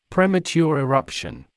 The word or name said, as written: premature eruption